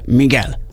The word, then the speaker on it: Miguel: Igen, egy kedves hallgató megírta, a Miguel kiejtése Miguel.